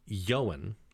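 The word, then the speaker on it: Ioan: And it was pronounced Yowen. – Oh, okay. – Almost like Ewan, like Ewan McGregor.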